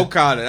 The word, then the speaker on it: Okada: Evil, Jay White, Minoru Suzuki, and Kazuchika Okada. Let me say that faster for you guys. – You're not going to say it, bro. – Okada. – Yo, Okada.